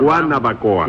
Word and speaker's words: Guanabacoa: Yo, porque no se dice Guenabacoa, sino Guanabacoa.